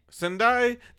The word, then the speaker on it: Sendai: Sendai, Japan, and that's gonna be May 30th through June 2nd.